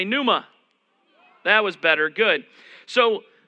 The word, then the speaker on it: pneuma: All right, but the P is silent, so it's just pneuma.